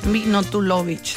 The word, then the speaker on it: Milutinovic: Estoy diciendo Minotuvoch, ¿está bien? Minotuno. – Es que así no se pronuncia, así se escribe. – Mino. – Ahí te voy. Bora Milutinovic.